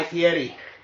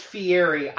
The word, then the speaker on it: Fieri: And it may be pronounced Fieri. The panelists were saying Fieri, so I'm assuming that they know how it's pronounced.